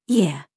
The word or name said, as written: YOU